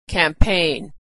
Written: campaign

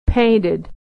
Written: painted

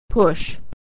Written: push

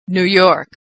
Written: New York

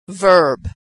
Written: verb

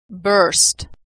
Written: burst

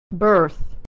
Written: birth

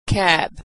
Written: cab

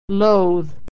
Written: loathe

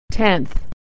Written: tenth

